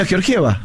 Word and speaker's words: Georgieva: Bueno, acá hay un oyente que nos dice en el YouTube, en el chat de YouTube, señores comunicadores, como el nombre es de origen eslavo, se pronuncia Georgiev. ¿Cómo lo pronunciamos nosotros, a Cristalina? – Sí, está como ella lo escribió. – Cristalina Georgieva.